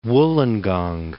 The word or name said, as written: Wollongong